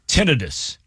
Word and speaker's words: tinnitus: Tinnitus.